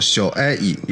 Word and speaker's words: Shoei: 日产这个头盔啊，其实咱们都知道有手 I，哎，为什么叫手 I？我跟大家说一下，我看了知乎上的这个学日语的一个回答，人说咱们通常读作收益，不对，它的这个拼写虽然是 S H O E I，它按照日文读音应该叫做小爱乙，有点像上海话哈。